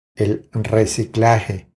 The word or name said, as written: El reciclaje